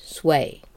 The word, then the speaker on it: SWEI: Today, you'll hear what Kelly learned at the follow-up webinar that looked in-depth at social wealth economic indicators, sometimes referred to by the acronym SWEI, pronounced sway.